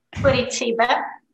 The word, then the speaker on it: Curitiba: Oh, I didn't ask you how to say this. Tell us what city. – Curitiba.